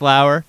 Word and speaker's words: Lower: Spelled lower, but pronounced Lauer.